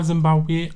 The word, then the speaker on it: Zimbabwe: Ich weiß nur, dass es im Land selbst auf jeden Fall Zimbabwe ausgesprochen wird.